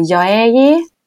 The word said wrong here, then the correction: Yaeji: Yaeji, Yaeji, Yaeji.